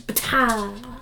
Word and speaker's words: Ptah: I know that that's not how you pronounce it, but it's spelled P-T-A-H. How Ptah. – Can you not pronounce it? Ptah. – It's Ptah. – See? – The P is silent.